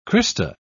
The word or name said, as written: crista